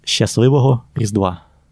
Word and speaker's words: Щасливого Різдва: Щасливого Різдва.